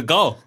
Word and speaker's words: gaol: And the jail is spelled G-A-O-L. G-A-O-L. – G-A-O-L. Yeah, there we go. – Good job. The three of you. – It's the goal.